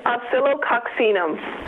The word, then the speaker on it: Oscillococcinum: Oscillococcinum.